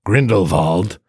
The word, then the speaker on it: Grindelwald: So today we are spoiling Fantastic Beasts, The Crimes of Grindelwald, or as it's pronounced in the movie, Grindelwald.